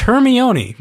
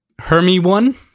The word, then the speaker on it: Hermione: Which, it's Hermione, right? – Hermione, but that's, yes. – I don't know. Fuck you. – That's how Hermione is spelt. – Hermie one? – Yes, it's spelled Hermie one, but it's not.